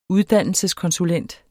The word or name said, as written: uddannelseskonsulent